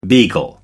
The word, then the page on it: beagle: British English